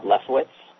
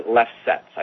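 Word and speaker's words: Lefsetz: And there's a gentleman by the name of Bob Leftwich. – Mm-hmm. – Or Leftsetz, I think is how you pronounce it. It's B-O-B-L-E-F-S-E-T-Z.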